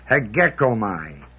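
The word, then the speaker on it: hegekomai (ἡγέομαι): The word count is the word hegekomai, H-E-G-E-O-M-A-I. Long E, first E is long E.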